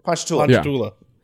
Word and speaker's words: Ponchatoula: Ponchatoula? – Ponchatoula.